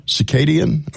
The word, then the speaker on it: circadian: Circadian.